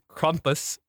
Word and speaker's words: Krampus: But Krampus, said correctly by our accent, is Krumpus, which sounds like you're like crumping.